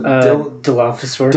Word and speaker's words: Dilophosaurus: It's the Dilophosaurus.